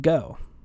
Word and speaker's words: Geaux: There is a YouTube channel called Go. It's spelled GEAUX, kind of a smaller channel.